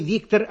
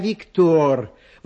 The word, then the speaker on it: Виктор: Во-первых, не Виктор, а Виктор. Во французском языке ударение на последнем слоге.